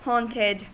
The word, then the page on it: haunted: American English